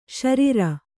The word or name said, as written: ಶಾರೀರ